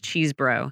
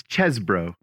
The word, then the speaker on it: Chesebro: Because all I can say in my head is Cheesebro. Oh, it's Chesebro.